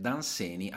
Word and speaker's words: Dunsany: Eh, il nome corretto sarebbe Danseni, alcuni lo pronunciano Danseni. È, una, è una, un'americanizzazione del, del, suo nome.